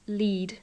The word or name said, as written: lead